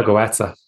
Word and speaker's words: Götze: So essentially, if you can use dopamine more effectively, whereas Mario Götze, Götze is that how you pronounce it? Mario Götze. – Goetze, you're more cultured than me.